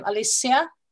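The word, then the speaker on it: Alicja: I think you pronounce her name Alicia. Is that the right pronunciation? – Very cool, yeah.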